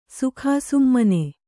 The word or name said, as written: ಸುಕಾಸುಮ್ಮನೆ